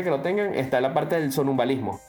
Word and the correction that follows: sonambulismo: sonambulismo, perdón, ¿eh? – lo dije mal.